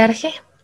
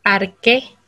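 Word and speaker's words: arché: Mm buscaron lo que se conoce como el arje, arque, arche, dependiendo cómo ustedes lo pronuncien. Aquí nos vamos a dar la libertad de pronunciarlo como se nos venga en gana.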